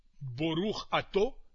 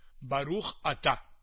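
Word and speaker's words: baruch ata (ברוך אתה): y bendito seas tú, boruch ato, en la pronunciación ashkenazí, pues nosotros lo pronunciamos baruch ata.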